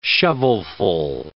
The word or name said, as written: shovelful